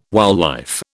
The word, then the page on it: wildlife: British English